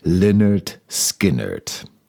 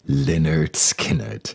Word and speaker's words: Lynyrd Skynyrd: Daraus wurde das kryptische Lynyrd Skynyrd, das auf dem Cover des ersten Major-Albums in Lautschrift erschien, damit man sich sicher sein konnte, dass der Name richtig ausgesprochen wurde. Lynyrd Skynyrd. Wir wiederholen noch einmal Lynyrd Skynyrd.